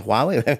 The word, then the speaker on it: Huawei: So I think the problem with Huawei, is that pronounced Huawei? – Huawei, yeah, that's the right word, yeah, Huawei.